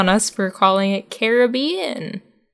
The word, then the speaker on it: Caribbean: This says that most natives of the islands pronounce the word with some version of emphasis on the first and third syllables. The pronunciation that's most accurate to the word's origin and most respectful to the island natives is the Caribbean.